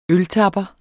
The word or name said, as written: øltapper